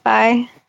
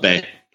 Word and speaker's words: Bai: Another one that I also like is Buy, which is are you guys familiar with the energy drink Buy? – No. – B-A-I. – Oh, it's B-A-I. Yeah. – Yeah. – I call it Bay. – Bay. – I've been saying it wrong.